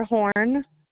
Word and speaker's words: Horn: Briar Horn. – Can you spell that? – B-R-Y-A-R-H-O-R-N.